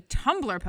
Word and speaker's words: Tumblr: you should read a Tumblr post.